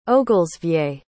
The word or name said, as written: ogelsvie